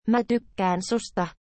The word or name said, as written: mae tykkaeaen susta